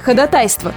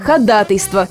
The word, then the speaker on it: ходатайство: На самом деле, ходатайство – просто грубая ошибка и не более. Единственный верный вариант, зафиксированный в словарях – это ходатайство с ударением на второй слог.